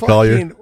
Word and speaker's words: Collier: Chris Barnett and how do you say his name? Collier? – Yeah, Jake Collier.